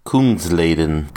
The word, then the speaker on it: Kungsleden: Kungsleden.